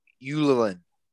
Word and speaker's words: Ylönen: Jesse Ylönen. It's spelled Ylonen. It's a Finnish name.